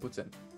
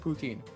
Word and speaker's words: poutine: A lot of different types of butsin. – What's that? – Butsin. You might pronounce it poutine, but... – Okay. What is it? I've never had it. – So, poutine is, first off, how we pronounce it.